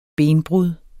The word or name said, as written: benbrud